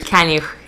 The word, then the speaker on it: Kaniuch: Kaniuch, yeah.